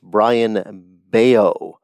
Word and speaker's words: Brayan Bello: But you look at this guy's stats, and you spell his name B-R-A-Y-A-N, and his last name is spelled B-E-L-L-O. It's pronounced Brayan Bello.